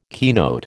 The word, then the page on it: keynote: American English